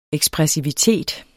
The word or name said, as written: EKSPRESSIVE